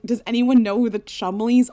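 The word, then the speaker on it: Cholmondeley: The best is that Chumley is spelled C-H-O-L-M-O-N-D-E-L-E-Y-S. So if I were to just try to pronounce it, I would say Chumondeles. – And they'd say, excuse me, it's Chumleys.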